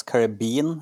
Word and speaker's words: Caribbean: Jeg tror det uttales Caribbean, Young.